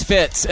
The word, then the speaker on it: Fitz: My name is Fitz, and I spell that F-I-T with a Z.